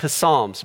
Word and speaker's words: Psalms: So for those of you who are new to the Bible, the book of Psalms, it's got the silent P, looks like Psalms, but it's Psalms.